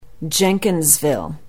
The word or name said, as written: jenkinsville